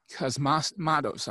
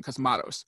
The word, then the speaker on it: Cosmatos: But he was replaced by George P. Cosmatos. I'm saying that wrong. Cosmatos.